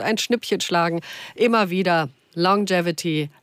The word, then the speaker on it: Longevity: Longevity habe ich richtig ausgesprochen? – Korrekt.